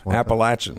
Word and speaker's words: Appalachian: Is that right? How you say it? Appalachian? They corrected us. – Appalachian.